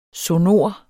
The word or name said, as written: sonor